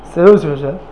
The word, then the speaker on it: Seleucids: Seleucids. How do you pronounce that again? Seleucids, something like that. Seleucids, yeah. Good.